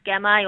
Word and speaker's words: Gamay: So I just think it's easy to pronounce Gamay, although some people might start calling it Gammy if they drink too many.